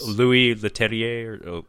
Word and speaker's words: Louis Leterrier: I think Louis Leterrier.